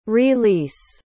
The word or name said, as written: re-lease